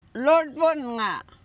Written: loordbennga

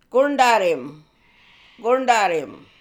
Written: Goorndarrim

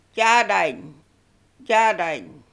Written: Jadany

